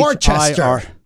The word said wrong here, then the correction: Worcestershire: No. And sorry. – I can say this because perhaps I have family members that were born in Worcester. It's Worcestershire.